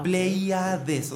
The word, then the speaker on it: Pleiades: Es con, con I latina Plei, Plei, Pléyades. Ah, Pleiades, o sea, es un poquito difícil pronunciarlo de esta manera lenta con, sus letras tal cual, Pleiades.